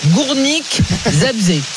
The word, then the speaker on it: Górnik Zabrze: Et donc, cette prononciation un petit peu compliquée. Je la retente. Górnik Zabrze. C'est pas mal, non? – Zabrze. C'est quasi parfait.